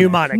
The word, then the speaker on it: mnemonic: Correction. It is M-N. – Thank you. That's what, okay. But it is pronounced mnemonic. – Mnemonic.